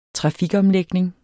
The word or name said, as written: trafikomlægning